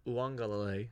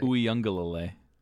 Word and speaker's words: Uiagalelei: DJ Uiagalelei. I think I said it right. – Uiagalelei.